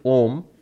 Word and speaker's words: Om: And because A and U together make the O, so we pronounce it Om because of that.